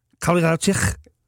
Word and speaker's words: Kowalkiewicz: Kowalkiewicz.